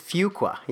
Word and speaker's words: Fuqua: It's Fuqua. Yeah, I know. – So they have this audio clip now that you have to check that you've listened to. And it goes, few qualify, Fuqua.